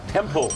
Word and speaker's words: temple: ท่านเขาบอกว่ายินดีต้อนรับเข้าสู่วัดนะครับท่านออกเสียงถูกแล้วครับ temple ตัว l ครับ pronunciation ตัว l temple